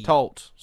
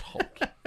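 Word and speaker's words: Toht: It's, well, it's spelled for T. – Tolt, sorry. – It is Tolt, okay. I thought the T may have been silent. – Not Holt. Tolt. Forget.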